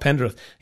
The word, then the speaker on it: Pendrith: Taylor Pendrith, am I saying that right? From Canada. – Pendrith. Yep.